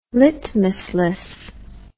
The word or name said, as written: litmusless